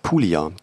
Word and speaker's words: Puglia: Ich glaube, es heißt Puglia. Das G ist stumm. Puglia, glaube ich. – Puglia.